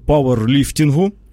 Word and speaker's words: пауерліфтингу: Ось, Наталя, у нас майстер спорту з пауерліфтингу. Я вимовив це слово правильно? – Супер.